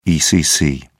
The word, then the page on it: ecc: British English